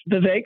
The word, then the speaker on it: Vivek: No, it's Vivek. Rhymes with cake.